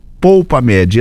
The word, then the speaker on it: Polpamed: Entra lá no aplicativo, digita lá Polpamed. Esse Pou aqui, o U, é com... Aí não é L não, é U, tá? E o D no final é mudo.